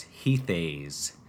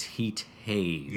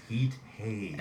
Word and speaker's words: Heat Haze: For about 30 years of being a Genesis fan, I have always pronounced the song in my head as Heath Haze. And I've now learned that it's Heat Haze. – Yes, Heat Haze.